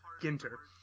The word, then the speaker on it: Ginter: It's a hard G. Ginter.